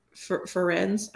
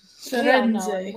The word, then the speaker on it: Firenze: But there is one, Ferenz, I'm going to butcher his name. I don't remember if it's Ferenz or if it's Fierenz or if it's Fierenez or whatever. – Yeah. Firenze. We all love him.